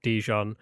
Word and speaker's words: Dijon: Is it Dijon? Dijon? – Dijon. – How do you say it? I don't Dijon. – Know. – Dijon. Like the mustard.